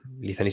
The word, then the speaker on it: Lithany: En YouTube, en el canal de Lizany, Lizany se escribe L-I-T-H-A-N-Y, un nombre muy antiguo.